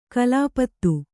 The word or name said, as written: ಕೊಲೆಪಾತ